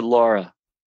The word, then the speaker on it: Lara: It's L-A-R-A. – Yeah, but she pronounces it Laura.